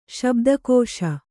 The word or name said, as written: ಶಬ್ದಕೋಶ